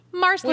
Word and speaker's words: Marsali: I learned one thing this week, that what I thought was Marsley is pronounced parsley. Marsley. – Wait, are you kidding me? – No, I'm not. Meryl said that apparently on an interview. – It's pronounced with a P? – No, it's pronounced like parsley, so it's Marsley. – Oh, I'm like, you are pulling my crap. No, okay, it's pronounced like parsley, meaning Marsley.